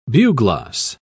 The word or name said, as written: bugloss